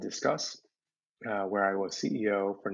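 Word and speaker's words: Disqus: So Discuss, which is pronounced as in discussion, but spelled in sort of a weird old school web 2.0 way with a Q.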